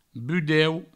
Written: budeu

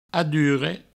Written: adurre

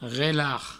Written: relarg

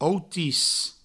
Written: outis

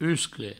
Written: uscle

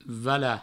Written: valat

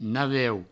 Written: naveu

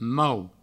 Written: mau

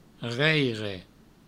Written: reire

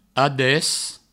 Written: ades

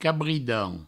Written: cabridan

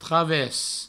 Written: traves

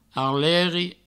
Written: arleri

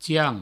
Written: tian

